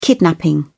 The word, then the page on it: kidnapping: British English